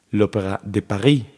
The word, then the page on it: Opéra de Paris: opeʁa də paʁi; franska